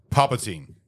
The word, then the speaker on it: Palpatine: There's no fucking N in the middle of the name. – Palpatine.